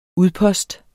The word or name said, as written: udpost